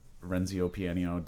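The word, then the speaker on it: Renzo Piano: Um, but the other thing I like like uh, this other museum I've been to a bunch of times that uh, Renzio Piano designed.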